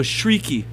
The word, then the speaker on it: Cherki: I don't think that that's how you pronounce it. It's tricky, because the R... – It's pretty tricky, actually. – No, that's actually how you say it. I'm being tricky. – No, Shrieky. – Shrieky? – Yeah, Wikipedia says Shrieky.